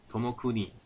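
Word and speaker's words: 友邦: 他并且由他目前公司的专务取缔役，也就是他的孙子，年仅三十一岁的石鹏邦，念作日基 Tomokuni，继任为社长。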